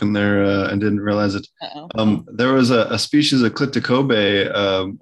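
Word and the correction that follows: Clitocybe: or I might be mispronouncing it, Clitacibe, whoever it is that they pronounce that. Psilocybe. – Clitacibe. – Thank you, Clitacibe.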